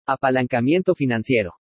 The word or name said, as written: APALANCAMIENTO FINANCIERO